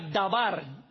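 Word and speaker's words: Dabar (דבר): La palabra promesa viene del hebreo, que significa dabar, D-A-B de bueno, como decimos nosotros, A-R. Dabar, con acento en la última.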